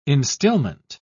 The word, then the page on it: instillment: British English